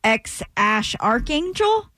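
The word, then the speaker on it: X Æ A-12: I mean, it's just X, the letter X, and then the AE is like pronounced Ash. And then A12 is my contribution.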